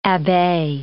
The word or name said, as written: abbe